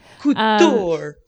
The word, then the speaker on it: couture: Couture. Couture. – That's how you say it. Sorry. Sorry, everybody. It's French.